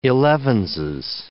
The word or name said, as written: elevenses